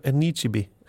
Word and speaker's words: Anichebe: Det må jeg si Det da Victor Anichebe, eller som det uttales, Victor Anichebe